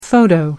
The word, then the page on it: photo: American English